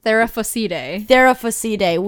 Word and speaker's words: Theraphosidae: We just looked up the pronunciation for this, and Theraphosidae.